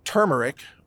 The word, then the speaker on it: turmeric: And, oh, if you're searching for turmeric, by the way, it's T-U-R-M-E-R-I-C. Or some people say turmeric or turmeric, whatever. Either way is okay.